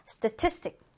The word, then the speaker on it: statistic: 자, 이번엔 두 번째 음절의 강세를 두고요. statistic. statistic. 이렇게 발음되는데요.